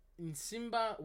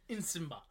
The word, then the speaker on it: Nsimba: Let me pronounce it. – The first name. – That is Nsimba Webster. Nsimba.